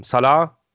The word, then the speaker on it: Saleh: Weil wir gestern die äh, Eröffnungspressekonferenz äh, von unserem neuen Head Coach Robert Saleh gesehen haben. Salah, so wird er ausgesprochen.